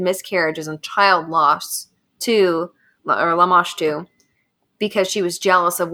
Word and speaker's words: Lamashtu: And also on the plaque is Lamashtu. Is that how you say it, James? – Yeah, Lamashtu.